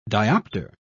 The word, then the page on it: diopter: British English